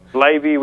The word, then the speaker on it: Blaby: Blaby, which rhymes with baby.